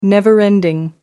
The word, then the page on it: never ending: American English